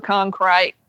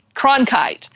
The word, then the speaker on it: Cronkite: He goes on and he's trying to play an anchor man like Walter Cronkite. I'm not saying it right. – Cronkite.